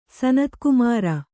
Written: ಸನತ್ಕುಮಾರ

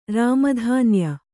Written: ರಾಮಧಾನ್ಯ

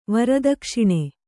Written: ವರದಕ್ಷಿಣೆ

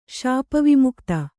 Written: ಶಾಪವಿಮುಕ್ತ

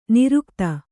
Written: ನೀರೋಗತೆ

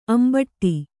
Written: ಅಂಬಾಡೆ

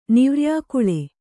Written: ನಿವ್ರ್ಯಾಕುಳೆ

